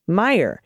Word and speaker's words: Meijer: Quick correction Representative Pete Meijer's name is pronounced Meyer, or how you would pronounce Meyer.